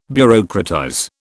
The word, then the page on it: bureaucratize: British English